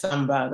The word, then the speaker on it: Samba: Yes, Samba, that's correct.